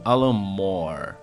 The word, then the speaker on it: Alan Moore: obra-prima de Alan Moore, ou como eles pronunciam, Alan Moore.